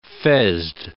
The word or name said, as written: fezzed